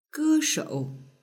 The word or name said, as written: geshou